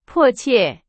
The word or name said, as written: 迫切